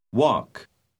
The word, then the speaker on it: walk: La letra L no se pronuncia. – Walk.